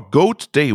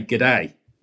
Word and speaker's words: g'day: It's good day or goat day. What? How do you pronounce that in Australia? – We would say g'day.